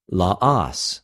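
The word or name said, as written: λαος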